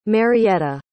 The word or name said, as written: Marietta